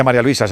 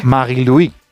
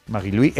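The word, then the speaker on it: Marie-Louise: Está María Luisa, ¿no? – Claro, bueno, sí. Bueno, no se dice María Luisa, se dice en su... – Magui-Louis. – Magui-Louis Eta, ¿no? – Así es.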